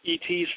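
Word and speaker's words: et (את): That's interesting, because that's how you pronounce the Aleph and Tav, is et, E.T.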